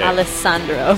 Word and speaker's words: Alessandro: It's Alessandro Cortini. – Wow, okay. – Alessandro. – Sorry. – I know that we've been saying it wrong. – It legitimately looked like his name had like four S's in it. – Alessandro. – It's only two.